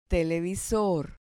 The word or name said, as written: televisor